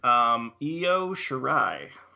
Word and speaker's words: Io Shirai: Io Shirai.